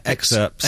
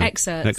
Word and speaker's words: excerpts: Excerpts. – Excerpts. That's a weird word, go on. – Isn't it? – I don't think you pronounce the P, do you? – No. Excerpts.